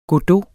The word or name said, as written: Godot